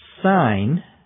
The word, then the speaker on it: sign: Ana, A-N-A, from Brazil, wants to know the difference between sign, S-I-G-N, and signal, S-I-G-N-A-L. Well, you can tell they're pronounced somewhat differently. Sign is pronounced with the G silent. Signal includes G in the pronunciation.